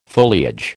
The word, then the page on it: foliage: American English